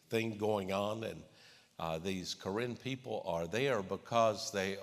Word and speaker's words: Karen: It's spelled as if it's K-A-R-E-N, Karen, my sister's middle name. And but it's pronounced the Karen people.